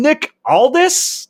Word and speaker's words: Nick Aldis: So bring in Nick Aldis. Am I saying that right? Correct.